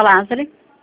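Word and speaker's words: Lazari: Marta Lázari.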